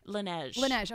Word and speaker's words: Laneige: Okay, and the Langley, is that how you say it? – Laneige.